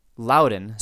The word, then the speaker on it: Loudon: Number two, on the East Coast here, folks pronounced Loudon so that it would rhyme with Cowden rather than Loudon.